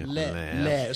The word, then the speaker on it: laird: Laird. A Scottish laird. – Lard is different.